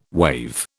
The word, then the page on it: wave: British English